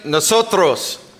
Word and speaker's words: nosotros: Say nosotros.